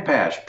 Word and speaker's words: Pasch: Ken Pasch, P-A-S-C-H.